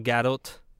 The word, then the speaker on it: Gadot: Kind of like recently when I watched Gal Gadot do something. And I was like, oh, that T is pronounced in that name.